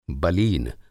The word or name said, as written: बलीन